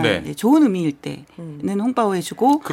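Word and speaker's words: 홍빠오 (红包): 홍빠오.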